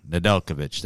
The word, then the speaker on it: Nedeljkovic: Nedeljkovic. Nedeljkovic, that's how you say it.